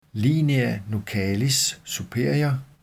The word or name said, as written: linea nuchalis (nuchae) superior